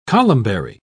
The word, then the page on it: columbary: British English